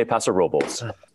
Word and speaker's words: Paso Robles: The locals say Paso Robles, though if you want to say the full name of the town, it's El Paso de Robles. But locals say Paso Robles or just Paso.